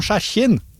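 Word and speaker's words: Hjerkinn: Harry skriver, hei, siden dere i det området, jeg kjørte noen opp til Dovrefjell, og jeg reagerte på at de lokale sa noe slikt som skjerkinn, når de mente jerkinn. Er det en normal uttalelse der oppe, at noen ord uttales helt uforståelig?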